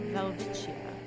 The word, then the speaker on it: Welwitschia: Alright, well, I definitely pronounced that wrong. It's a Welwitschia.